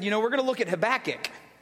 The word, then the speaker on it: Habakkuk: Turn with me to Habakkuk.